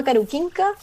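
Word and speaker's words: Karukinka: El parque se llama Caruquinca, esa es la pronunciación en, en castellano, lamentablemente no puedo pronunciarlo en Selknam.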